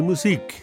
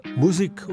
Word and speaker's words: Musik: Beispiel die Musik. Die lateinische Musica heißt auf Englisch Music und in Süddeutschland Musik oder Muse. Im Norden Deutschlands allerdings sagen sie Musik, also mit der Betonung auf der zweiten Silbe.